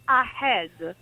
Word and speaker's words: ahead: Aggiungono l'H all'inizio, che non serve. Per cui dico, no ragazzi, staccate la A dal resto della parola, a head.